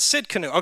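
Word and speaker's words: Tsidkenu: Jehovah Tiskanu. I'm probably butchering these names, just so you know. Sidkanu, is that how you say it? Sidkanu. Okay, so the T is silent. It's like tsunami.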